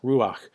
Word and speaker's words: ruach: And it's an ancient Hebrew word that I pronounce ruach, but it not only means breath, but it means spirit.